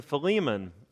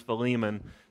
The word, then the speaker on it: Philemon: It's a little letter to Philemon. That's how I say it. I know there's lots of different pronunciations out there, but in South Alabama, he's Philemon. So we're going to stick with that this morning.